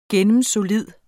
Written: gennemsolid

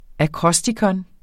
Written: akrostikon

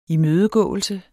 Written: imødegåelse

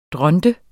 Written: dronte

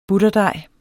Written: butterdej

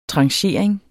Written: tranchering

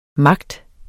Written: magt

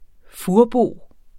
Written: furbo

